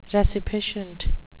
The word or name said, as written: resipiscent